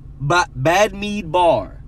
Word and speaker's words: Bamidbar: Numbers translates to Bamidbar. I think I'm pronouncing that right. B-A-M-I-D-B-A-R. Bamidbar.